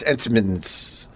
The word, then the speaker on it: Entenmann's: And you're saying it wrong. It's Entenmann's. – I said Cuban way. – It's Entenmann's. – El Entenmann's. – If you pronounce it properly, it's Entenmann's. – Put some maracas in there, Joey. – Look at it. Look how it's spelled. It's pronounced Entenmann's.